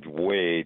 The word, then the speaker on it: Wei: Two professors at University of Wisconsin, one's named Wei, W-E-I, that's how I pronounce it. The other, Q-I-N,